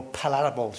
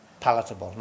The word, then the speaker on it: palatable: Something that is a bit more palatable to, is that the way to say it? Palatable, not palatable.